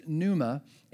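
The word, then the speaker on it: pneuma (πνεῦμα): Now, the Greek word that's used for spirit here is pronounced pneuma, and it means wind, breath, or spirit.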